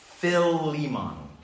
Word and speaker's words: Philemon: Philemon.